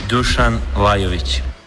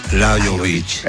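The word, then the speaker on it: Dušan Lajović: Dusan Lajović. – Dusan Lajović. – Exactamente. – Lajović. Aparece como una I, la J como I.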